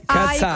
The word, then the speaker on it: Katsi: Si scrive K-A-T-S-I, sì, Cazzai, come occhio.